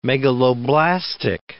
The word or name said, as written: megaloblastic